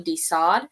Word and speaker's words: de Sade: de Sade. – Yes. Published. De Sade. Did I say that right? Yeah, you did.